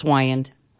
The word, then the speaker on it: Wiegand: It's Wiegand. – Wiegand. No, mm-hmm. G. The G is silent.